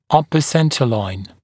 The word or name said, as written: upper centre line